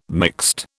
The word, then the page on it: mixed: British English